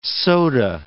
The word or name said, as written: soda